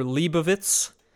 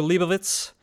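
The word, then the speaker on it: Leibowitz: It is called A Canticle for Leibovitz. It's spelled with a W, but I'm saying it correctly. A Canticle for Leibovitz.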